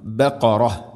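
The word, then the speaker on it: ബഖറ (بقرة): തസ്ബഹു ബഖറ, ഖാഫിൻ്റെ ഉച്ചാരണം ശ്രദ്ധിക്കണം. ബഖറ.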